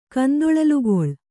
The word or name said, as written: ಕಂದೊಳಲುಗೊಳ್